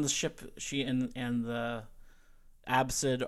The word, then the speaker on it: ABCD: Asdid is his name, or Absid, because it's his name is spelled ABCD. So everyone calls him Absid.